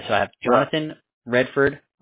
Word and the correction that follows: Radford: Radford, I'm sorry, I pronounced that incorrectly. – Yeah, R-A-D-F-R-D.